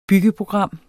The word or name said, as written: byggeprogram